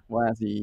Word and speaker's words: WASI: WASI, yeah, WebAssembly system interface, right? – Correct.